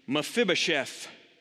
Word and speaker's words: Mephibosheth: So say this with me, Mephibosheth. Mephibosheth. It's not an easy word. Kind of a little bit of a silent E, a little bit of a silent O, Mephibosheth, but you could say, but Mephibosheth.